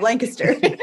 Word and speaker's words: Lancaster: I know people out there say Lancaster. – Yeah. Good job, Lancaster.